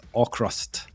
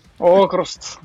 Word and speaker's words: AVKRVST: So tonight joining us, we have Norwegian prog band AVKRVST. How are you guys doing? – Good. – Good. – Yeah. – He is pronouncing that right. – That was perfect. – That's fine. – AVKRVST.